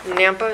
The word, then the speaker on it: Nampa: Your Nampa, is that how you pronounce it? – Yes.